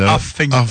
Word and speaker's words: Huffington: David Chabot écrit aussi pour le Huffington Post, si je le dis bien. – Il y a pas de T dans le milieu, c'est Huffington.